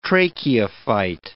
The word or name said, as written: tracheophyte